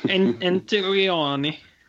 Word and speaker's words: Antonioni: Antonini. – Antonioni. Right? – Yeah,